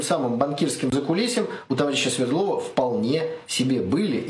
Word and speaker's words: Свердлова: Так вот, лично я считаю, что в убийстве царской семьи решающая роль именно Свердлова. Или, как иногда ставят... ударение Свердлова.